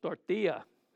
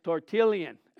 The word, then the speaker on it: Tertullian: I thought it was Tortilla. And somebody said, no, it's not Tortilla. It's Tertullian.